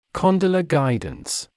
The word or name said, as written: condylar guidance